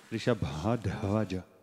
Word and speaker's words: Vrishabha dvaja: So Vrishabha, the bha is again the bha with the bh. And dvaj is again with dha. – Dvaj is also dha. – Vrishabha dvaja. – Vrishabha dvaja.